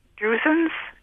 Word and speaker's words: drusen: I've heard the term drusens.